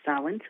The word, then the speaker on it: Samhain: It's spelled differently because it's Celtic, but it is pronounced Samhain, so thank you for that.